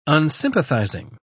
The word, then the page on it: unsympathizing: British English